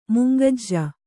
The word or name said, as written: ಮುಂಗಜ್ಜ